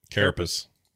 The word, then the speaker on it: carapace: Carapace. – Yeah. Carapace. – Yeah, I mean, you were close. You put emphasis in a weird way that made it seem dirty.